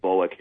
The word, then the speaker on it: Volokh: Volokh is spelled V-O-L-O-K-H.